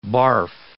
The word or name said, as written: barf